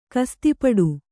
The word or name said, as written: ಕಸ್ತಿಪಡು